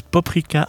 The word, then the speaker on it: paprika: Le Eiger 2012 Angus Bicaver Andrea est servi avec le poulet paprika, dont je rappelle on prononce paprika en hongrois.